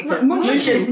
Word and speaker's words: München: And then Russell Crowe is like, no, Munich's a city in Berlin, and then pronounces it in the German way. – München.